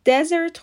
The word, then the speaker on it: desert: D-E-S-E-R-T به معنای بیابان دزرت خونده میشه و استرس روی سیلاب اوله.